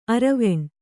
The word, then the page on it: ಅರೆವೆಣ್ಣು: Kannada